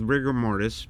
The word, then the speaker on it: RGMRTS: So the band that you're touring with coming up this weekend, Friday, as a matter of fact, they're called Rigor Mortis, but they spell it RGMRTS, because I'm guessing there's a million bands out there called Rigor Mortis.